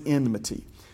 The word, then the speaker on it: enmity: What is enmity? It rhymes with enemy, but I'm not saying enemy. The word is enmity, E-N-M-I-T-Y, enmity.